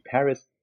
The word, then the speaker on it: Paris: Paris is a very significant role in this book, because we're talking about all these authors from Paris that came in translation to West Germany. Paris, or Paris, as she would be pronounced in German, was Peter Gente's fiancée, Heidi Paris, by now deceased.